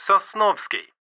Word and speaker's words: Sosnovsky: It's N-I-K-K-I-T-A. – Thanks. – B. – What's your surname? – It's Sosnovsky. – Can you spell that, please? – Sure. It's S-O-S-N-O-V-S-K-Y.